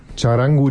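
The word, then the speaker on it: Txarango: Schreibt sich auch mit TX am Anfang, spricht sich wie Charango, dieses kleine Instrument, ich sage nur Ukulele, hatten wir auch als Spezialinstrument hier eine Themensendung, ist so ein ähnliches Instrument.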